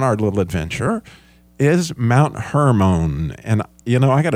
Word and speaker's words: Hermon: It's H-E-R-M-O-N, not like Herman Munster, H-E-R-M-A-N. But Hermon. And that mountain, those letters have to do with the start. You hear the R in there, that resh, R, that her, like you heard the sure, right?